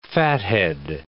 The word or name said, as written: fathead